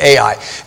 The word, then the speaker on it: Ai: You spell it when you say it, Ai.